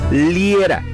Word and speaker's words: Liera: Si quieres dejar alguna recomendación, mis redes sociales también te esperan, Fernando Liera. Mi apellido se escribe L-I-E-R-A.